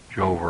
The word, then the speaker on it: Jehovah: We find in the book of Genesis the three primary names of deity: Elohim, Jehovah, or Yahweh, is probably more correctly pronounced, but Jehovah is a historical pronunciation in English, and Adonai.